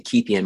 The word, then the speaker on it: Keithian: That's spelled I like an iPhone, and then Keithian, my name, K-E-I-T-H-I-A-N.